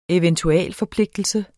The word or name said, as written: eventualforpligtelse